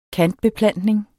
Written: kantbeplantning

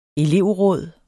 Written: elevråd

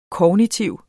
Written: kognitiv

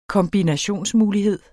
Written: kombinationsmulighed

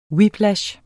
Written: whiplash

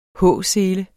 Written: H-sele

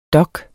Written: dok

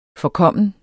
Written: forkommen

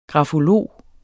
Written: grafolog

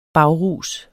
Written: bagrus

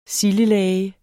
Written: sildelage

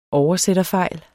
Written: oversætterfejl

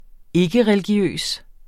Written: ikkereligiøs

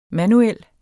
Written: manuel

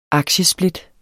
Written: aktiesplit

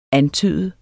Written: antyde

